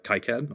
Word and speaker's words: KiCad: So I use something called KiCad or KiCad, depending on how you pronounce it. I hear people say it both ways.